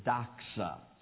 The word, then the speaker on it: doxa: It actually is the Greek word is the way we would pronounce it is doxa.